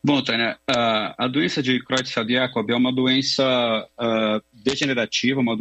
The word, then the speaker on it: Creutzfeldt-Jakob: É essa mesma a pronúncia? Pra gente... – É, Creutzfeldt-Jakob, tanto faz.